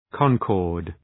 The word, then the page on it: concord: {‘kɒŋkɔ:rd}